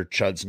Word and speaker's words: Chud: Robbie Bloodshed seems to have mispronounced Dr. Chud's name. He calls him, what is that word? Chodes? Oh, you mean Chode. Chud.